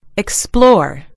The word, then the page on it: explore: British English